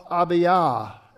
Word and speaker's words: Abiah: The course of Abiah, as it's pronounced in Hebrew.